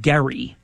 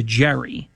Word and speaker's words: Gerry: I mean, if you know about where gerrymandering came from, there was a governor by the name of Eldridge, and he pronounced it, I believe, Gerry, G-E-R-R-Y, but it turned into Jerry,